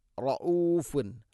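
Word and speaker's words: റഊഫുൻ (رؤوف): റബ്ബന ഇന്നക നൂൻ മുഷദ്ദഗുന്ന, ഇന്നക റഊഫുർ റഹീം, റഊഫുൻ, റഊഫുർ റഹീമല്ല, റാഇനെ കനപ്പിക്കണം, എന്നാൽ റ എന്നായി പോകരുത്, റഊഫുൻ, റഊഫുൻ, ഹംസ, റഊ, ഹംസയുടെ ഉച്ചാരണം മഖ്റജ് തൊണ്ടയുടെ താഴെഭാഗം.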